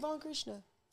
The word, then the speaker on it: Von Krishna: I thought you said Don. That's why I was looking like Von.